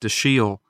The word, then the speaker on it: Dashiell: But it's useful to at least try to remember that I'm told by expert sources it's pronounced Dashiell. Hammett.